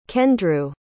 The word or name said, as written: kendrew